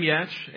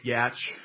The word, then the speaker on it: Yatch: So my name is Tim Yatch, and it's a long A, Yatch, if you don't know how to say it.